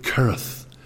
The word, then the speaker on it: Cherith: Well, if you know anything about John Douglas in his Hebrew class, he'll tell you the CH is always pronounced with a K sound, like the brook Cherith.